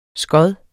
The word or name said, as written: skod